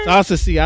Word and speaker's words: Saunsoci: Ed Saucony, man, in the building. I said it wrong, huh? – Yeah, it's all good. – I always do. I always Saunsoci. – I put a knee and not a C.